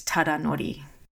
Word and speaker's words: Tadanori: And his first name is Tadanori.